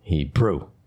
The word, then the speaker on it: Hebrew: Emphasis on the second syllable. Brew. Hebrew.